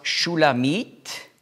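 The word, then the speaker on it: Shulamit (שולמית): Auf Hebräisch spricht man aus Shulamit und Shlomo.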